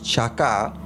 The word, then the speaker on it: chakah: And the fourth one is called Chaka, and it's spelled C-H-A-K-A-H, means to wait earnestly with a loving anticipation, Psalms 33 and 20.